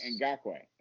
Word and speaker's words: Ngakoue: I think Ngakoue, I'm just going to start. – So I looked it up. The K is in the pronunciation. – It is it. Okay. – Yes. – Ngakoue.